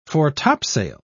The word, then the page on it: fore-topsail: British English